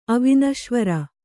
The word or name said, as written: ಅವನೀಶ್ವರ